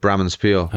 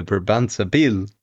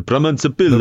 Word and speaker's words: Brabantse Pijl: I call it Bramenspiel. – Okay. But let's ask the winner. – In the Belgian way, it would be the Brabantse Pijl. – The Brabantse Pijl. – The Brabantse Pijl. – Yeah, that's it.